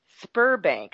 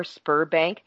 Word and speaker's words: Sberbank: The past one and the one before, Spurbank, I think that's how they pronounce it, S B E R, Spurbank.